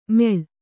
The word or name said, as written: ಮೇಳ